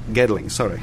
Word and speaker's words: Gedling: He was elected as Member of Parliament for Gelding, is it? That's how you pronounce it? – Gedling, in 1987 and re-elected in 1992.